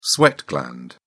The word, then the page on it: sweat gland: British English